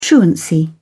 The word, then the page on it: truancy: British English